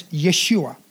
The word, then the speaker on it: Yeshua: Now, two of those 12 were Caleb and Joshua, or another way to pronounce his name is Yeshua.